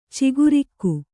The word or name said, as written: ಚಕ್ರಿಕ